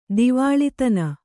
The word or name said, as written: ದಿವಾಳಿತನ